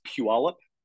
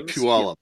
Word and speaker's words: Puyallup: Um, like, are you, are you from Paliup or Tacoma? So so yeah, it's it's called, it's pronounced Puyallup Oh, sorry, Puyallup. Yeah, sorry, Puyallup.